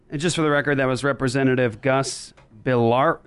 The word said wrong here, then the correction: Bilirakis: Oh, I can't even say his name. It's a tough one. Bilirakis. Bilirakis.